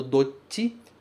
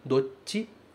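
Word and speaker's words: Dochi (どっち): Cuidado com a pronúncia do dochi. Porque ele tem um tsu pequeno. Logo tem uma pausa. Dochi. Dochi.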